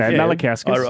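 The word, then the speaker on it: Malinauskas: It's close. – That's not bad. – Yeah, it's pretty not good. Malinkauskas. – I wrote it phonetically. So anyway, he's... – And still added an N in there, did you? – He came into the Rush Hour over there.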